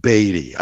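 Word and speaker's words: BADIE: BADDIE, B-A, actually it should be pronounced Beatty. I keep getting that wrong because it's only one D, B-A-D-I-E,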